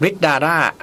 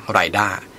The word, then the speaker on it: Ridar: Ridara RD6 นะครับทำตลาดที่จีนเนี่ยเขาใช้ชื่อว่า Ridar